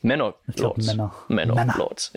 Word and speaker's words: Manor Lords: Manor Lords. – Ja, genau. Vom gewissen Greg. – Du kannst es auch so aussprechen, wie wir immer die Dings, diese lustige Serie aussprechen. Bly Manor.